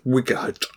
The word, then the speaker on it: Wight: Spelled W-I-G-H-T for those. – Yes, yes, Wigert. – A Wigert. – Yeah, yeah. Wigert. Yeah, Wigert. You just pronounced it wrong.